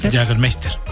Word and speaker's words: Jägermeister: Jägermeister.